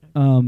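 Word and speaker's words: Ugli: It's actually an ugly fruit.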